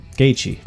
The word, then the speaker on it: Gaethje: But Gaethje, that's how you're supposed to pronounce it.